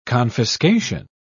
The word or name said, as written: confiscation